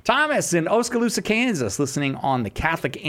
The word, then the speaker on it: Oskaloosa: Gosh, great job pronouncing the name, first of all, Oskaloosa.